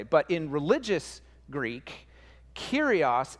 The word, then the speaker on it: Kyrios: Now, in the Greek that this hymn and this letter was written in, the word behind Lord is the word Kyrios. Okay, say it with me, Kyrios. Kyrios, great, you got it. If you're trying to spell it, it's like curious, but with a K and no U. I don't know if that helped, but just sound it out.